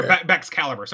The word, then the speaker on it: Baxcalibur: Baraxlix, I think is how you say it. – I'm not sure. – Baxcalibur, sorry, Baxcalibur.